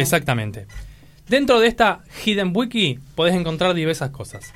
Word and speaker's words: Hidden: Es, se llama la Hayden Wiki. – Ah, Una Wikipedia. – Sí, Wiki, Hayden no, Hidden. Hidden, se pronuncia Hidden.